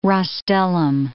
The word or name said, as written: rostellum